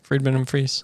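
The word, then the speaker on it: Friedemann Friese: Is it Friedman and Fries? Friedman and Fries? – Yeah.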